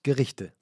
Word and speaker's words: Gerichte: Gereshet, how do you say that? – Yeah, geniale one-pot Gerichte.